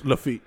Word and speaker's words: Lafitte: It was first structured in the 1770s by the pirate known as John Lafayette. Did I pronounce that right? I know you had it down. – It's Lafitte.